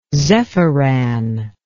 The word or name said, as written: zephiran